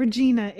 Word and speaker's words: Regina: They tried to explain, like, it rhymes with fun, right? It's not Regina. It rhymes with fun, or it's a part of a lady's body, as they say.